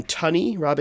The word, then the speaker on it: Tunney: It's also got Robin Tunney, I don't know how to say her name. – It is Tunney.